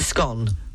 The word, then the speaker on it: scone: I believe it's a scone. Rhymes with gone.